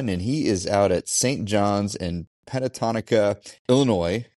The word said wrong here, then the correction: Pecatonica: Did I say that right? – So yeah, Pecatonica, close.